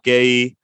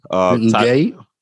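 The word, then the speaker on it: Guéhi: There were a couple of debutants, Carl Walker-Peter, Mark Gay. I don't know if I'm pronouncing his name correct. – Gay, yeah, something like that. Yeah. – That's how I say it.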